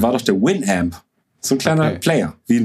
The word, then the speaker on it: Winamp: Winamp, so spricht man es aus.